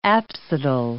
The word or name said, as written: apsidal